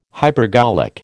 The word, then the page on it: hypergolic: American English